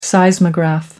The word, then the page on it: seismograph: American English